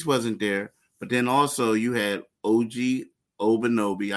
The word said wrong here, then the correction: Anunoby: I just butchered his name. – Anunoby. – Anunoby.